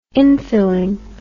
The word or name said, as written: infilling